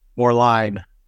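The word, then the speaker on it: Moerlein: But how does Rheingeist compare to, now I may get the name wrong, so you have to correct me on this, more lean? – More line. – More line, okay.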